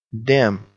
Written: damn

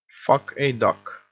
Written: fuck a duck

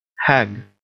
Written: hag

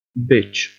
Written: bitch

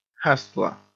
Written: hustler

